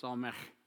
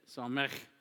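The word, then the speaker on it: Samekh: And the 15th letter of the alphabet is pronounced Psalmech. Actually, it's Psalmech. So you got to sound like you're getting ready to spit. Psalmech. That's how a Hebrew would say it.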